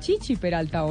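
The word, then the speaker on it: Chichí Peralta: pero además Chichi, yo creo que él, él mismo se llama Chichi Peralta, ¿o no? No, es Chichi Peralta, con acento en la I, una palabra aguda.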